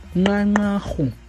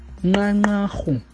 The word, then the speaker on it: Nqanqarhu: And Maclear is now Nqanqarhu. Nqanqarhu. Nqanqarhu.